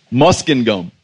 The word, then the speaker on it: Muskingum: How do you guys say it? Muskegum. You know what it means? Okay, one dude say it. Muskingum.